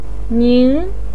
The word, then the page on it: ning: 2